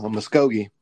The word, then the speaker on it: Muskogee: She was born in, uh, I had it on tip of my tongue, Muskogee. – Muskogee. Oklahoma. Mm-hmm. That is correct. She was born in Muskogee to Carol and Steve Underwood.